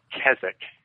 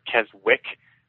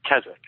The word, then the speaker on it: Keswick: It's pronounced Keswick. It's spelled K-E-S-W-I-C. So some people pronounce it Keswick because they don't know the W silent. It's Keswick, and that's a small town in the Lake District of England.